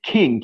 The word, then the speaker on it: Kinh: But he has a really good friend named King, K-I-N-H is pronounced King.